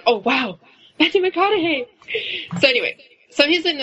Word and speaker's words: McConaughey: Matthew McConaughey. Matthew McConaughey.